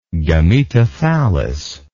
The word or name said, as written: gametothallus